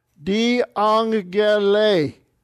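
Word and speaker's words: Di angele (διαγγελῇ): Hoti, another quote from Exodus, ace, auto, tuto, exegera, se, exegera, se, hopos, endexomai, en, soi, tain, dunamin, mu, kai hopos, di angele, accent on the end. Remember, double gamma, double G is pronounced NG. Di angele.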